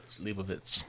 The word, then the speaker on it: Leibovitz: Oh God, I'm gonna say Lulovitz. How do you say that name? How do you say that? Leibovitz. – Say that again. Leibovitz. See, that sounds like a sneeze.